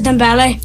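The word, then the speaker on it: Dembélé: The answer is Dembele.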